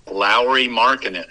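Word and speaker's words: Lauri Markkanen: Lowry Markkanen.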